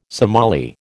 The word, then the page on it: Somali: American English